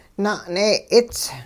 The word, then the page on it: na'nee'its: hupa